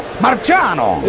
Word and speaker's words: Marciano: Rocky Marciano. No está mal, pero debemos pronunciarlo al italiano. ¡Marciano!